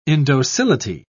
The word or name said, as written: indocility